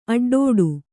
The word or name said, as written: ಅಡ್ಡಡಿ